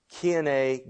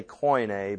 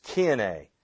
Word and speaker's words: Koine: Now, some say Koine, but I actually talked to a Greek tour guide, and she said the correct pronunciation for that is Koine. We spell it like this, and it's usually pronounced Koine.